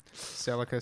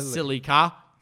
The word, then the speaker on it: Celica: And why'd you say Celica? – I always figure which way is the right way to say it. – Celica, silly car. – Celica. Not silly car.